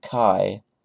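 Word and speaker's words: Kai (χ): It was very, very dumb of them to give the weapon a name that sounds like the other term that gets thrown around a lot. So for clarity, I'm just going to pronounce it Kai.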